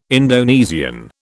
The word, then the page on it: Indonesian: British English